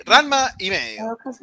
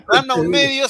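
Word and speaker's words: Ranma ½: Sí, Ranma y medio. Solamente voy a decir así para molestar a Martín. Ranma un medio se estrenó la nueva serie, la remake. – Bien, ¿no? bien dicho. – No, pero lo dije mal antes a propósito.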